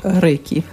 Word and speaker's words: Рейки: Рейка, вернее, правильно говорить, да? Рейка для семьи и детей. Нет, рейки. – Рейки. Ой, я а. была права, да? Интуитивно я назвала правильно.